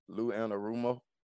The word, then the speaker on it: Lou Anarumo: You know, I, I just I, I applaud Lou and uh, let me see say this right. Uh, Lou Anarumo. – Is, there you go.